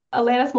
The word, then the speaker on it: Alanis: It's like Atlantis. – Atlantis. – Yeah, and but no T. – Alanis? I'm gonna go with Are you trying to say her name? – Yeah. – Alanis Morissette? – Yes.